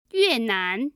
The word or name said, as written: yuenan